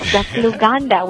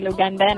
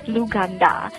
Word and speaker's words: Luganda: The language that we are talking about is Luganda? – Correct. You said it right. That's Luganda without an N on the end. Most people say Luganda, and I'm like, no, there's no such thing. It's Luganda.